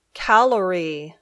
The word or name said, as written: calorie